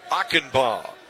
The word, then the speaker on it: Achenbach: 0-2 the count, and Trevor Achenbaugh. Last name A-C-H-E-N-B-A-C-H, but last part pronounced Ba, Achenbaugh.